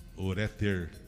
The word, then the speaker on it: ureter: A sílaba tônica é no ré. Então é Ureter.